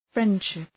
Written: friendship

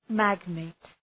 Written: magnate

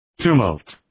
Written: tumult

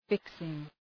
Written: fixing

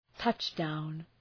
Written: touch down

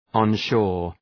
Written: onshore

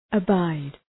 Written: abide

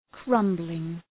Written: crumbling

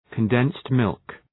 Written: condensed milk

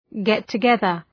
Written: get-together

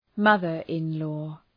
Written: mother-in-law

